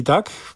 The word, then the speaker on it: Duck: Und weil du es gerade so ausgesprochen hast, ich glaube, der echte Kenner sagt Duck und nicht Duck.